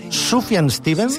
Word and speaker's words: Sufjan Stevens: És, com ho pronunciem, Sufjan Stevens.